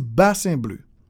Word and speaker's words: Bassin Bleu: So the number one is Bassin Bleu.